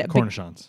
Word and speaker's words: cornichons: And Cornichons. – Cornichons.